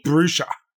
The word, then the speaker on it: Bruxa: And he's living with, like, a vampire lady that's called a Bruxa or Bruxa or Bruxa or something like that. – Any one of those pronunciations is fine. – One of them Brexits. – I just forget what they said in the episode already. – Bruxa. – Okay, that one.